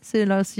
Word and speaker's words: Scylla: Scylla, dites-moi, ça se prononce comme ça ? – Scylla.